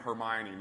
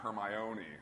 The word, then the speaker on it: Hermione: Because I know, we were talking about this backstage earlier, I was crushed when the Harry Potter movies came out, and they pronounced her name Hermione, not Hermione.